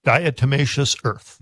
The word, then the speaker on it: diatomaceous earth: Diatomaceous earth.